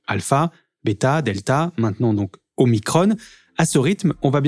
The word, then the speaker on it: Omicron: Il faut dire Omicron pour une raison simple, expliquée. C'est comme epsilon. Voilà. En grec, quand un mot se termine par on, on prononce on, Omicron.